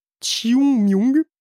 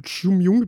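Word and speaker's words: Qingming: Attention, Xiongming. Ça s'écrit Kingming, mais bon voilà, ça se prononce un truc comme Xiongming, parce que même avec la prononciation YouTube, j'ai quand même beaucoup de mal, désolé.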